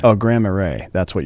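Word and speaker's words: Marais: If it's M-A-R-A-I-S, it's Graham Array. Yeah. – Okay, great. Great. It's very Mardi Gras. It's got a Mardi Gras pronunciation.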